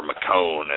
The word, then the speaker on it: Michonne: I'll agree and disagree with you about Michonne or McCone, as some people seem to think it's pronounced. I don't think I've heard a clear one, but I'll go with Michonne, because that's what I always... – McCone's really lame. – That's what I always envision, but no, I think she does serve her purpose as Rick's number one soldier, you know.